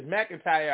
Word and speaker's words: McIntyre: But my last name is McIntyre. I've heard people say, like, it wasn't nothing, McKintry.